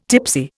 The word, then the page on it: tipsy: Indian